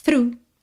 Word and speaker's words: through: Nós temos THROUGH, mas olha, through. Through, that's all.